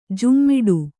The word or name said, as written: ಝೋಮ್ಮಿಡು